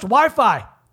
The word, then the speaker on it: WHYPHY: WHYPHY. Pronounced Wi-Fi.